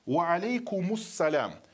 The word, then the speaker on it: ва алейкумуссалям: И вот эта буква «а» не произносится. Не произносится. То есть мы тут говорили «ассаляму алейкум», тут говорим без нее. То есть остается удвоенная буква «с».